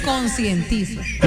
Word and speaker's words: concientizo: Yo sé que no hay luz, me consentizo, que no, no hay luz. – ¿De qué? – Me consentizo. Padre. – Consentizo no se dice, se dice concientizo.